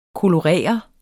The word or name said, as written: kolorere